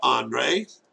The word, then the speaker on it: Andrei: My first name is Andre, A-N-D-R-E-I.